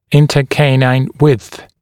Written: intercanine width

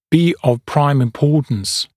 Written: be of prime importance

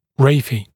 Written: raphe

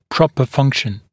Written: proper function

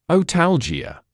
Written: otalgia

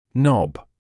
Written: knob

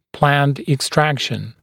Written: planned extraction

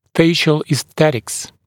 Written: facial esthetics

